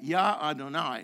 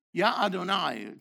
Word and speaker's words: Yah Adonai: Now, the Torah is actually full of incidents where Yah Adonai, okay, which is the correct way to say that, Yah Adonai, like it is in Isaiah 12:2. Yah Adonai, Yah Adonai can be provoked to anger.